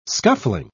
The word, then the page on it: scuffling: British English